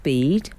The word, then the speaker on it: Bede: And I think this will really clear up the pronunciation for people who are keen to pronounce it Bede. – Oh, well, they're just being, aren't they? – Yeah, silly. – Oh. So, it's definitely Bede.